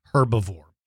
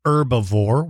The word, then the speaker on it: herbivore: It lived in the late Jurassic, that's about 155 to about 145 million years ago, and it is an herbivore, or you might pronounce it herbivore. Uh, just so that you know, herbivore is an animal that eats plants. So some people call it herbivore without pronouncing the H. Some people pronounce the H like herbivore.